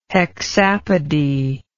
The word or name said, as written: hexapody